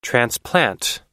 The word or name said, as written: transplant